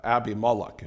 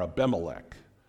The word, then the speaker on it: Abimelech: Pretty ugly, pretty brutal, but this was this man's name, Abimelech, if you will, or Abimelech, as we pronounce it.